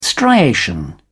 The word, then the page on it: striation: British English